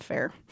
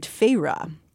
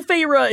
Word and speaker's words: Feyre: As the book opens, we're seeing things from the eyes of a young woman named Feyre, which thankfully Sarah works a clever pronunciation of this name onto page 13 of the paperback because I probably would have been calling her fa- fair, fair the entire time.